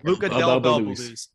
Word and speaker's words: Luca Del Bel Belluz: I know I saw you tweeting about uh, Luca Del Bel Belluz. Did I say that right, or did I get it yeah. backwards? – Yeah. Luca Del Bel Belluz.